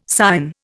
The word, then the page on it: Sion: Indian